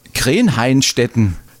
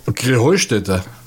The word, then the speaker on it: Krähenheustätte: Ja, ihr sagt immer Krenheinstetten. Und dabei heißt es doch Krähenheustätte, oder wie? – Krähenheustätte. – Ja, genau, und das ist die Betonung auf dem Heu.